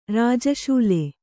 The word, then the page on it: ರಾಜಶೂಲೆ: Kannada